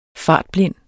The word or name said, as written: fartblind